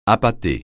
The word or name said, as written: appâter